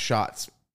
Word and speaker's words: Shatz: His last name was spelled S-H-A-T-Z. – Shats? – Shats. He always said it was German. It's pronounced Shots.